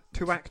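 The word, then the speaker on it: Tuwaiq: And then we've got the, well, how do you pronounce this? Tuquach? – Tuac Trophy.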